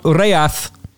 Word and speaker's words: Ghaiyyath: Because we want Ghaiyyath, to give him his proper pronunciation. Thank you for having that confirmed for me by Arabic colleagues over the weekend.